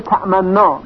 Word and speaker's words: تأمنا: اشمام در کلمه تأمنا به این صورت هست که در لحظه ای که نون مشدد رو تلفظ می کنیم لبها رو قنچه ای می کنیم.